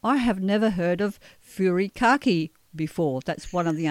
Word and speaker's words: furikake: So let's start off with the first one, furikake. I think I'm pronouncing it correctly. – Yes, I think furikake is correct. Our Japanese friends might correct us, but we'll stick with that.